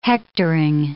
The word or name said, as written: hectoring